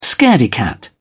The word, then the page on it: scaredy cat: British English